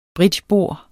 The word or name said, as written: bridgebord